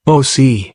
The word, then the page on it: OC: German